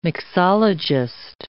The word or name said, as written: mixologist